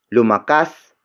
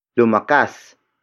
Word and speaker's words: lumakas: Word for word, it's lumakas ako ng dahil sa iyo. Lumakas ako ng dahil sa iyo. Lumakas ako ng dahil sa iyo. The first word is lumakas. Lumakas. Lumakas. Lumakas is spelled as L-U-M-A-K-A-S.